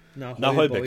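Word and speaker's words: Naheulbeuk: Und zwar in den Verließen von Nahoyböck. Oder Nahoybeck. Ich kann es nicht aussprechen. – Nahoyböck, richtig. Das war's richtig. Nahoyböck ist richtig.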